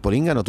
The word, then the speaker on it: Polígono: La más equivocado, tú no vives ya en el Polígono, tú ya te has cambiado de casa.